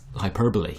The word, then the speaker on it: hyperbole: See, the word is hyperbole.